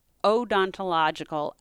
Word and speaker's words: odontological: the first to be convicted based on odontological evidence.